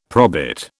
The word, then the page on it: probit: British English